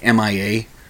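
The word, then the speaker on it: M.I.A.: CeeLo Green came out, Nicki Minaj, and Mia, spelled M period A I period A period, like MIA. I do believe she pronounces it Mia.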